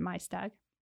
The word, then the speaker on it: Maesteg: It looks like Maesteg, but I'm pretty sure they pronounce it Maesteg.